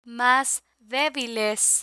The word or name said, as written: mas debiles